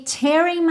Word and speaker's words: tear: E uma curiosidade desse trecho é que esse verbo tearing, ele soa muito como tearing na pronúncia da canção. E aí a gente tem um outro significado, porque o verbo to tear é rasgar e o verbo to tear, que se escreve da mesma forma e a diferença é só mesmo a pronúncia, significa lacrimejar.